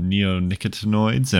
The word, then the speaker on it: neonicotinoids: All right, now Nick is going to tell us about neonicotinoids.